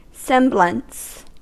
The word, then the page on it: semblance: /ˈsɛm.bləns/